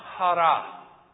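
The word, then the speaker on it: hara (χαρά): It's spelled C-H-A-R-A in the Koine Greek. Well, the English translation of that. C-H-A-R-A, but it's pronounced hara, hara, hura. You know? That's kind of what it sounds like to me.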